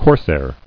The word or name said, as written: Corsair